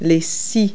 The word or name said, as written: saws